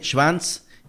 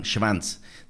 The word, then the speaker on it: Schwanz: Ella es Sabine Schwanz, directora, lo pronuncié en alemán, Schwanz, directora Travel, Trade and Connectivity de Madrid Destino, que es el del Ayuntamiento de Madrid. Saludos, Sabine. – Hola, buenos días. Lo has pronunciado perfectamente, así que yo me reconozco.